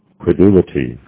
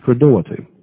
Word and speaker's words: credulity: And this is how it's pronounced. – Credulity. – Credulity.